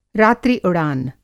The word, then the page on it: रात्रि उड़ान: Hindi